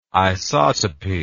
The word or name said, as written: isotopy